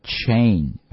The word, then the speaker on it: chain: 注意它的发音要读 a，所以读的是 chain， OK， a chains， OK。